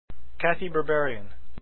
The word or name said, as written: Berberian